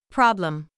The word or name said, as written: problem